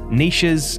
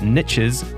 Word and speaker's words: niche: In my interviews with each expert, we covered identifying your target audience, niches, and, by the way, if you pronounce that word niches, you're wrong, where to find your audience, how to connect with them, and the sort of content you should be producing for them.